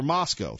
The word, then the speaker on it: Moscow: And I thought to myself, or Moscow, thank you for that correct pronunciation, Sweet William.